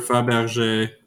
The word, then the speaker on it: Fabergé: Oh, no, it is for sure Fabergé. Okay, I thought that. I didn't realize there was an accent on the end.